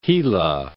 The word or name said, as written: Gila